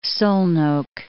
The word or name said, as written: Szolnok